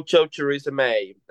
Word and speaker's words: Choriza May: It's called Cho Choriza May, and it's just to teach people how to pronounce my name because British people can't pronounce it.